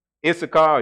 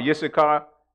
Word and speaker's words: Issachar: The tribe of Yisrael, of Yisachar, called, how you say it in the English? I forgot how to say it. Now I'm saying it in Hebrew. So Issachar, or Yisachar,